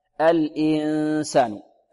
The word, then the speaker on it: الإنسان: ويحذر طالب من أن ينطق غنة في الهمزة، يبدأ بالغنة من الهمز، يقول يومئذ يتذكر صوت من الخيشوم، لا، الإنسان، الإنسان.